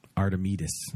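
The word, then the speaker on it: Artemisia: Artemis? – Yeah. No. Artemidas. – Oh, sorry. – Yes. You put the accent on the wrong syllable.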